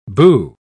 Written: boo

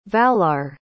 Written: valar

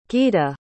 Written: Gyda

